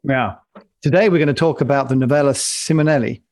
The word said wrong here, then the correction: Nuova Simonelli: Did I say that right? Novelli. How do you say it, Max? It's an Italian. I can't do Italian. – Nuova Simonelli. – That's what I said, isn't it? – Not nearly.